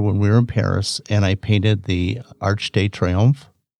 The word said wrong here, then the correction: Arc de Triomphe: Arc de Triomphe, yeah. – Yeah, I said that right? – Yeah.